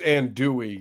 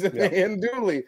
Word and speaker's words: Andouille: Andouille sausage. Isn't that what it's called? – I think it's Andouille. You don't pronounce the L's.